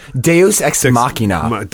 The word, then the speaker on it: Deus Ex Machina: When you, because what's the, there's a term, Dux Ex Machina? – Deus Ex Machina.